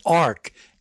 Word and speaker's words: ARCH: The library is called ARCH, A-R-C-H, but it's pronounced ARK, A-R-K.